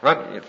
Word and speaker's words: Runcorn: In a little town called Runcorn, and I don't know if I'm pronouncing that right. – Runcorn. – Thank you, sir.